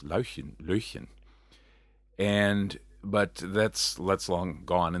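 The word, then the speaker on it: Löwchen: It should be Löchen, because it means little lion, basically. And it's a very old breed. He's a cross. But Löchen, or as Kiwis pronounce it, Lauchen, is a very old breed that goes back to, I think, the 16th century, maybe even earlier than that.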